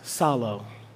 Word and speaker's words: psallo: The word here in the original language is solo. Solo. Everybody say solo. Solo. P-S-A-L-L-O. Solo. It's like the Psalms, the P is silent.